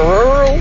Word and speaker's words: rural: I can't say the word that's spelled R-U-R-A-L, like a country area. – Oh. – Like, do you say rural or rule or rural? – Rural. – I can't do it. – Rural. It's rural.